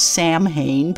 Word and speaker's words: Samhain: Samhain, which by the way is spelled S-A-M-H-A-I-N, it looks like it should be pronounced Samhain, but it's not, it is Samhain, was a day that they did indeed celebrate on October 31st.